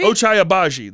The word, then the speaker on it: Ochai Agbaji: Ochai Agbaji? – Ochai Agbaji.